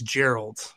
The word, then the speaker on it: Geralt: It's The Witcher season three, which I'm really excited for, not just because it is Henry Cavill's last outing as Gerard. Well, actually, is it Gerard? I'm butchering his name. Yes, it's Geralt. Geralt.